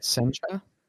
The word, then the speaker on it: Sencha: I think I'm pronouncing right. Sencha? – Yes, that is correct.